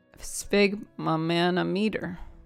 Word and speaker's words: sphygmomanometer: No, it's sphyg. Sphyg. – Sphyg. – Momanometer. Sphygmomanometer. Momanometer. – Sphyg. – Sphyg. – Sphygmomanometer. – Yeah. Like that. – Sphygmomanometer.